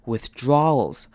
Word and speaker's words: withdrawals: 首先看到前面的 i 念成短母音 i 的声音，with withdrawals withdrawals。这边的 owls 有没有发现非常特别？a w 念成 r 的声音，withdraw withdraw。